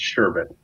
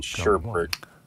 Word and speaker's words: Sherbet: Sherbet. There's no R in there. No, it's Sherbet. Stop saying it wrong. – I will go to my grave saying Sherbert because I want to.